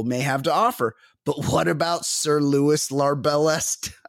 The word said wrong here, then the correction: Larbalestier: I can't even pronounce it, the new name. It's rude of him. Larbalestier. Larbalestier.